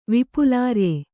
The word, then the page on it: ವಿಪುಲಾರ್ಯೆ: vipulārye